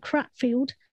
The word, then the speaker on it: Cratfield: And then after that, I moved to this tiny little village called Cratfield, which is spelt Crat Field, not Crap Field. It's crat.